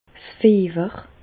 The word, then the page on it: 's Fiewer: Bas-Rhin; Reichshoffen